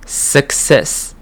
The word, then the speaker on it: success: 这个地方的两个 C 呢，它两个 C 的发音是不一样的。第一个 C 是 k，第二个 C 是 s。 Success， success。